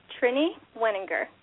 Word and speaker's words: Trini Wenninger: It's Trini Wenninger.